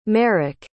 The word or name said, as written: merric